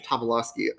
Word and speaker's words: Tobolowsky: How do you pronounce his last name? – Tobolowsky.